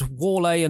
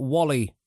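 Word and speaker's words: Whalley: Why is it pronounced Wale and not Wally? Why is it not said as it's spelt?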